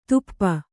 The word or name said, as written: ಧೂಪ